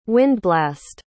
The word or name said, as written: windblast